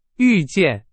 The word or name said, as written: 遇见